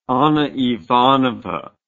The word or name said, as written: anna ivanovna